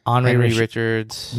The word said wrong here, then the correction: Henri Richard: Henri Richard. – Henri Richard.